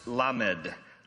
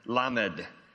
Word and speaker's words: Lamed: Psalm 119, starting at verse 89, you'll notice this new Hebrew letter pronounced Lamed, Lamed.